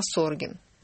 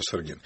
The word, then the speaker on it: Саргин: Кстати говоря, нам Петр почему-то пишет, что не Саргин, а Соргин. Правильно произносить. – Правильно произносить, Саргин.